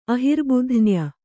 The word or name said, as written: ಅಹಿರ್ಬುಧ್ನ್ಯ